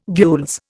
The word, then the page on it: gules: Indian